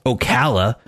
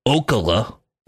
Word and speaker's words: Ocala: Nobody on the set, even the script supervisor, doesn't know that that town is pronounced Ocala, not Ocala.